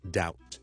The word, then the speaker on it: doubt: 最常见到我哋成日都讲 silent letter 嘅例子咧，就系我哋怀疑 doubt 好怀疑一件事，中间个 b 咧，我哋系唔需要读出嚟，所以我哋唔读成 doubt， but doubt，当系好似 out 个 out 啦，加个 d 喺前面就系个 doubt。